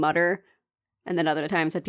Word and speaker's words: Mütter: What I remember was he was born in America and went by Mütter and then traveled to Europe and learned that it's pronounced Mütter and changed it. The website on the Mütter Museum says to pronounce it Mütter. So I'll be pronouncing it Mütter.